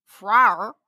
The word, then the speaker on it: Phwoar: and she says she explains a little something to us from her last email, and it is that Phwar, spelled P H W O A R, is pronounced like I just said it, means to express desire.